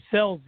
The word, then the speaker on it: Scelzi: Also, in the number 16 this year, you've got, am I pronouncing it right when I say Gio Scelzi? – It's Selzy, it? Gio Selzy.